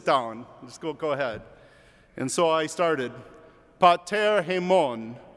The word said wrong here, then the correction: Pater: And he goes, "It's Pater." The accent's on the first syllable.